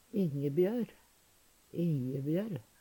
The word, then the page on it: Ingebjør, Injebjør: Numedalsmål